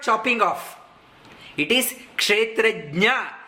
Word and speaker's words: Kshetrajna: The name itself, we should learn how to pronounce properly. Many people, they say, they can't say Kshetrajna, they say Kshetrajna. Gna and Jna, there is a difference.